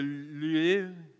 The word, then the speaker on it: Louvre: This is the Louvre, okay, that's how you say it.